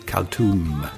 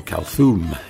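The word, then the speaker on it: Kalthoum: Movement 1, Alf Leila Wa Leila, from his excellent album Khaltoum, or Khaltoum, if you pronounce the TH.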